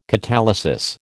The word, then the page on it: catalysis: American English